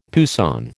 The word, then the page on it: poussin: American English